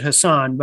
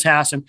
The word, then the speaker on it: Hassan: Uh, uh, Republicans prefer to pronounce it Hassan, but it, it is Hassan.